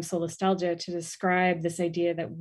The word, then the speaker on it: solastalgia: And you introduced to us this concept of solastalgia.